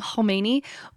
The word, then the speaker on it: Khomeini: Because I'm terrible at the huh, I'm just going to call him Khomeini, but his name starts with a K, but it's a silent K.